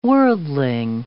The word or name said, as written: worldling